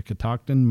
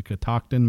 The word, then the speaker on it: Catoctin: The Catoctin Mountains. The Catoctin Mountains. – Catoctin? – C-A-T-O-C-T-I-N.